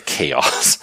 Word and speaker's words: Chaots: You know, it's spelled a funny way, but it basically is pronounced like the Chaos.